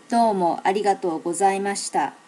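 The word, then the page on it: domoarigatogozaimashita: native Japanese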